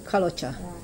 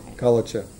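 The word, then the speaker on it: Kalocsa: It's quite different from the, how do you say it, Kalocha? – Kalocha. – Kalocha.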